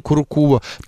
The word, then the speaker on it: куркума: Мы тут выяснили, что ударение в этом слове куркума.